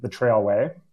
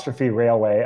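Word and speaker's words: T'Railway: And from Deer Lake, I did opt to get onto the trailway. – Oh, okay. – T-railway, as it is spelled.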